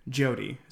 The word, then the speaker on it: Jyoti: but I believe it's Jody. – So, yeah, I think you're right, Jody. – Jody. So it's spelled J-Y-O-T-I.